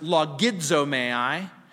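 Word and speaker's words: logizomai (λογίζομαι): The word accounted is logizomai or logizomai, depending on how you want to pronounce it. But I pronounce it logizomai.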